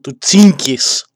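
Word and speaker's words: Τσίνκις (Чингис): Έρχεται λοιπόν σε επαφή με τον Κότουν Καν, εγγονό του Τσίνκις, όπως προφέρεται τελικά και όπως αποδεικνύουν οι γνωσολόγοι, Τσίνκις Καν,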